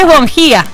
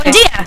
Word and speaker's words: Bongia: No, es Bongia, efectivamente. – ¿Bongia? – Dice, falso acento en la I, me dice, y no significa buen día, pero está escrito para que suene fonéticamente así.